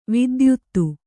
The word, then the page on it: ವಿದ್ಯೋತ: kannada